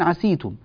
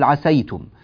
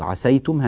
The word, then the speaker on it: عسيتم: يقرؤون برواية قالون أو برواية ورش يقرؤون عسيتم، لكن نحن نقرأ برواية حفص فنقول عسيتم، فننتبه إلى هذا يعني من باب ألا يعني يتعجب الإخوة في بلاد المغرب من هذا التلفظ، عسيتم هكذا بفتح السين